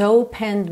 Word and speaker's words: opened: Well, you came and opened me. Open me. Atenção que o E final do opened não é pronunciado.